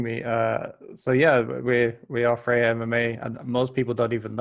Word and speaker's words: Freja: Freja MMA.